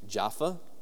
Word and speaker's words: Jaffa: Now, this is the southernmost part of modern-day Tel Aviv-Yafo, otherwise pronounced Jaffa.